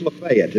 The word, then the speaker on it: Lafayette: It is Lafayette, is that right? Did I say it right? Lafayette.